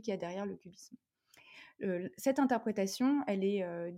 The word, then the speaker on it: QBism: Alors, cubisme, ça s'écrit Q-B-I-S-M, le Q pour quantum, et, et c'est l'acronyme en fait de quantum Bayesianism. Donc, c'est une interprétation qui est dite bayésienne de la mécanique quantique.